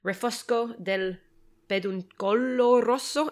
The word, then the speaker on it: Refosco del Peduncolo Rosso: But actually, specifically, Refosco del Peduncolo Rosso. Am I pronouncing that right? Was that a terrible attempt? – No, absolutely. It's perfect.